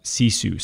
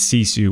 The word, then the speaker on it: sisu: But the one thing that I took away from all of it was this idea of the Finnish concept of sisu. So it's S-I-S-U and is how it's spelled.